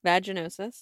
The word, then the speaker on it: vaginosis: Vaginosis.